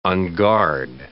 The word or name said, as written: unguard